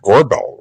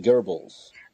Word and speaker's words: Goebbels: Uh, there's no R there. It's not Gorbel, right? The minister, sinister minister of truth for Hitler. I think, I it's you pronounce it Goebbels.